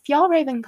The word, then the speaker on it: Fjällräven: It's the Fjällräven Kanken backpack, Fjällräven rhymes with y'all, and I don't know if any of y'all have the backpack or have heard about it.